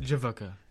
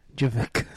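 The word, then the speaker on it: JVKE: It's spelled with a V instead of an A. It looks like an upside down. – J-V-K. – So I pronounce it Javuka. – Javuka.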